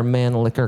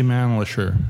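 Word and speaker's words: Mannlicher: And if so, if they look up your name, or because I see it's under Man Liquor Carcano, yeah, is that the correct pronunciation? – That is the correct, but we say Manlisher. – Yeah, okay. – To distinguish it.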